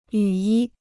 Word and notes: 雨衣: yǔ yī

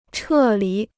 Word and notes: 撤离: chè lí

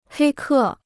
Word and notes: 黑客: hēi kè

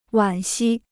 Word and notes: 惋惜: wǎn xī

